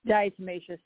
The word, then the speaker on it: Diatomaceous: Did I pronounce that correctly? – Diatomaceous, yeah.